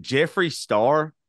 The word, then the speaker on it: Jeffree Star: But is it Jeffree Star? – Oh.